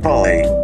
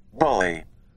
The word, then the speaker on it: bullae: How do we pronounce the B-U-L-L-A-E? – Bully. – Bully. Bully. So it's almost like B-U-L-L-Y. Bully. except it's fancy smancy with the B-U-L-L-A-E.